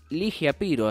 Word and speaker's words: Ligia Piro: Estamos hablando de Ligia Piro,